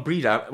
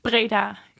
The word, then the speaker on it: Breda: Um, but you're from a town called Breda. Well, I'm probably pronounced differently, is it? – Breda. – Breda.